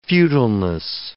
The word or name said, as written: futileness